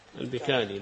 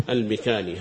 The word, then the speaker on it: البكالي: البكالي الباء بكسر الباء، يقول البكالي بكسر الباء، نوف البكالي، هكذا اضبطوه إذا نطقتم به، نوف البكالي بكسر الباء، البكالي تخفيف بالتخفيف، يقول بعضهم ضبط بكسر الباء وتشديد الكاف، وهنا ذكر بالتخفيف، التخفيف، طيب.